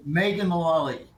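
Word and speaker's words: Mullally: Um, Megan Mulian Mulally Mulally Mulally Mulally. Oh, Megan Mulally. – There yeah, we go. Okay. Thank you for pronouncing it for me.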